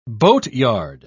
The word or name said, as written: boatyard